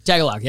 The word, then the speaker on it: Tagalog: Tagalog. – Tagalog, yes.